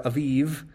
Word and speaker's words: Aviv: But other texts inform us that the opening month is Abib or Aviv, is how you'd pronounce it in Hebrew.